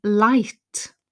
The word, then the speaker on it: light: They can share data using light, L-I-G-H-T, silent G.